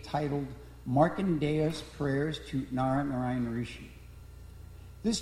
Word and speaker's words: Narayana: Clearly, Narayana, four syllables, and we commonly just shorten it to three.